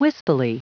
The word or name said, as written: wispily